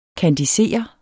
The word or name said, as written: kandisere